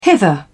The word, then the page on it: hither: British English